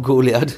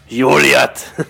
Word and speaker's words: Goliath: ausgesprochen ist es auch Goliath. Joliath. Joliath. Mit J vorne im Rheinland.